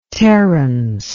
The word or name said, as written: Terrence